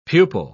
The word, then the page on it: pupal: British English